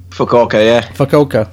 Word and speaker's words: Fukuoka: Fukuoka.